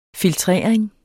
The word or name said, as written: FILTRERING